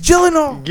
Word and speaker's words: Gyllenhaal: Is it Gyllenhaal? – It's Gyllenhaal.